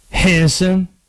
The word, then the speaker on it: Henderson: Spelled just like that. Henderson. You want to know how the people in Henderson pronounce it? Henson. Two syllables. Henson.